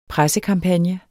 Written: pressekampagne

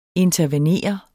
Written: INTERVENEREDES

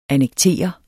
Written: annektere

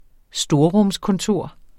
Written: storrumskontor

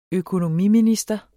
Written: økonomiminister

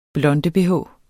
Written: blonde-bh

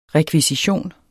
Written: REKVISITIONS

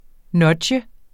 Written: NUDGE

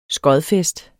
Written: skodfest

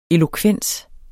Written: elokvens

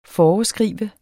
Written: foreskrive

